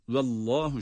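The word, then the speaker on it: Vallahu (والله): Bunu dikkate alarak Vallahu e'lemu diye Vallahu şeklinde okuyoruz.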